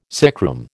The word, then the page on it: sickroom: American English